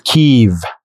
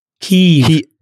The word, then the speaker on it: Kiev: But the spelling, the transliteration in English that the Ukrainians use, which has now become like a culture war thing, is K-Y-I-V. And in my best Ukrainian accent, which is total garbage, it is kind of a longer I. It's not, but it doesn't turn into an E. It's Kiev. – Kiev.